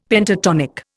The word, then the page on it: Pentatonic: Indian